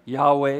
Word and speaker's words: Yahweh: YHWH, no vowels in there. Yahweh is how we pronounce that.